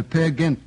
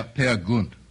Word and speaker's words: Peer Gynt: In the Peer Gynt suite. – Yes, you're mispronouncing that terribly. – Well, of course. – Peer Gynt.